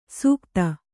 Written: ಸುಗತ

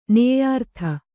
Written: ನೇಯಾರ್ಥ

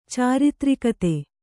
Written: ಚಾರಿತ್ರಿಕತೆ

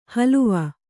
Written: ಹಲುವ

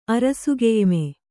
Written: ಅರಸುಗೆಯ್ಮೆ

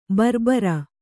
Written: ಭರಭಾರಿ

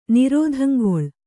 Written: ನಿರೋಧಂಗೊಳ್

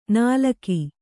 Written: ನಾಲಕಿ

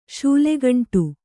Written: ಶೂಲೆಗಂಟು

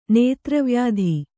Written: ನೇತ್ರವ್ಯಾಧಿ

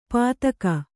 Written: ಪಾತಕ

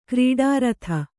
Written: ಗರುಡರಥ

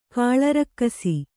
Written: ಕಾಳರಕ್ಕಸ

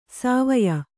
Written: ಸಿವಾಯಿ

